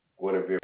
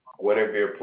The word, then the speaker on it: Gwenevere: Guinevere Plunkett. – I'm sorry, what is it? – Guinevere Plunkett. – Guinevere? – Yeah, Guinevere. – Can you please spell it for me? I barely can hear you. – G-W-E-N-E-V-E-R-E.